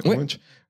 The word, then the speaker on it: Munch: Dans Munch, ça se prononce comme ça, ouais. Munch.